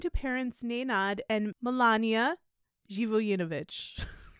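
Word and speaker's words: Živojinović: Because it's spelled Zivojinovic. Zhivoyinovich. There you go.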